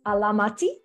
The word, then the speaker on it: Alamati: it's Alamati. If yes. I'm saying that correctly, okay. – Correct.